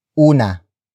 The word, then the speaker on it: una: The first exception is una.